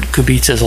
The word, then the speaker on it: Kubica: And lastly, just wanted to mention the proper pronunciation of Robert Kubica's last name. It's actually pronounced Robert Kubica. I'm a native speaker in Polish, so I guess I have the authority to say that.